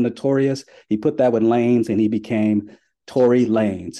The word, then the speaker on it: Lanez: lanes. So L-A-N-E-Z is pronounced Lanes in his name.